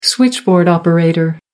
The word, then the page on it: switchboard operator: American English